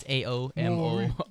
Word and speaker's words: Aomori: But it's Aomori.